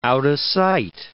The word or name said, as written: out-of-sight